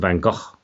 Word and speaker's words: Van Gogh: But I was I've been to Amsterdam, so I've actually been to the museum of Vincent's and looked around, and I was told by the Dutch curator there that it's actually Vincent Van Gogh. And that's how you pronounce it, the Dutch way of saying it.